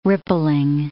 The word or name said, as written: rippling